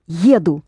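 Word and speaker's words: еду: Da, jedu, pronounce it accenting the first vowel.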